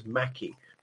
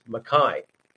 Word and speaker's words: Mackay: I remember when I first saw that name written when I was about 13, thinking it was Mackie. Of course, it's a Scots surname pronounced Mackay.